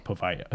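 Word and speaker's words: Poveglia: It's spelled like Povaglia or something, right? – But yeah, but it's like called, weird. It's like Povaia, or Povaia, Povaia, Povaia.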